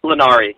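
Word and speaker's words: Linari: I think her last name is pronounced Lanari. Is it Nancy Lanari? – Lanari, yeah.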